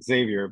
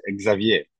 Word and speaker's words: Xavier: Yeah, in English is Xavier, but in French, for example, is Xavier. So it's Xavier. – It's another accent. Yeah.